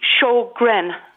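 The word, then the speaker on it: Sjögren: And it's pronounced Sjogren.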